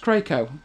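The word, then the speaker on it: Kraków: They mean Krakow? Krakow? Pronounced Krakow.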